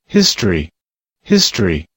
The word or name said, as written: history